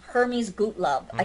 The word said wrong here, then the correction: Gottlieb: I can't pronounce the name. Excuse me. Gottlieb.